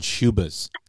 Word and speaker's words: Schubas: Look into playing at this place called Shubas. – Shubit? How do you spell that? – It's Schubas. Shubas.